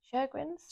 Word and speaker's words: Sjögren's: But I also was diagnosed with, and I think it's pronounced Sjögren's syndrome. – Sjögren's.